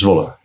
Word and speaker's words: Zwolle: We're in Zwolle. – Yes. – In, did I say that right? – Yes, Zwolle.